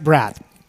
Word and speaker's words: Brad: Uh, my parents uh, were not not crazy creative, but my entire life, like people go, oh, it's Bradley, it's Bradford. No, it's it's straight Brad.